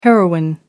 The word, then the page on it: heroine: American English